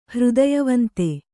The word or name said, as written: ಹೃದಯವಂತ